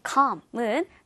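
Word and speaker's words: calm: 다음 단어 calm이라는 단어도 역시 이 l 사운드가 나지 않아요. 그래서 l이 없는 것처럼 발음하는 게 포인트입니다. calm은 차분한이라는 뜻이고요.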